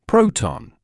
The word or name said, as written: proton